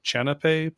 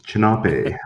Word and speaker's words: Chanape: General introduction here with your, excuse me if I'm mispronouncing this, Chanape project? Is that how you pronounce it? – Yeah, you're close. Chanape.